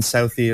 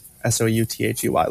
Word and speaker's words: Southey: But Alex is spelled the usual way, typical way, and Southey is just Southey, like the direction, and then EY.